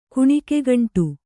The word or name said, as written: ಕುಣಿಕೆಗಂಟು